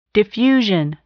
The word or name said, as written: diffusion